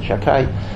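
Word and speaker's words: Shaddai: Shakai, which is Shin Dalaj, we pronounce it Shakai, is one of the names of Hashem that you cannot erase.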